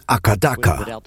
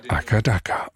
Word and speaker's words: AC/DC: Die Aussprache von AC/DC in Australien, die geht so. – Die Leute sagen Akadaka, ohne Querstrich. – Akadaka, okay.